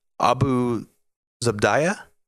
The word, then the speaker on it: Abu Zubaydah: and that is Khalid Sheikh Mohammed and Abu Zubaydah. Is that how you pronounce his name? – Yeah.